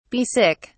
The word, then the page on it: be sick: British English